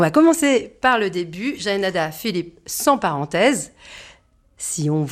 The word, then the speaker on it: Jaenada: Mais quand on me demande la manière de le prononcer, moi je dis Jaenada,